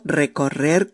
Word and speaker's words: recorrer: Os la recuerdo para que fijemos este verbo un poquito, el verbo recorrer, que sé que es muy difícil de pronunciar. Bueno, se deletrea así. R sencilla, E, C, o, R doble, E, R sencilla de nuevo. Recorrer, recorrer, recorrer.